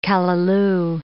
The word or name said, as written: callaloo